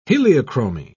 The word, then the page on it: heliochromy: British English